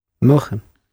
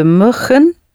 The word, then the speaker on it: mogen: Make it a nice long O. – We mogen het. Mogen. – We mogen. – Not mogen. Mow. Mow. Like in mowing something. Mow the lawn.